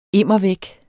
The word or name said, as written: immer